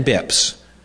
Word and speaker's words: bps: So if you ever, and sometimes it's abbreviated BIPS, BPS, and pronounced BIPS.